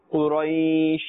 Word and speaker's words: Quraysh: When we are stopping, we will say Quraysh, but don't stretch the sound of this sheen. – Quraysh. – This will be incorrect as well. In the normal ma'roof manner, you must pronounce Quraysh.